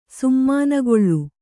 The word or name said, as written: ಸೀಮೆನೇಗಿಲು